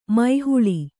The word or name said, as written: ಮೊಹಲ್ಲಾ